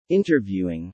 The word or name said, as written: interviewing